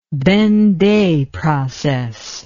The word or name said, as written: benday process